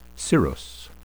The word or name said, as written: cirrhus